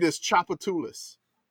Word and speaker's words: Tchoupitoulas: But the name of the street, is Tchoupitoulas. – Pennsylvania and Louisiana, distant cousins. – The PA to LA connection. – So the third syllable is the emphasis on Tchoupitoulas. – Tchoupitoulas, that's right.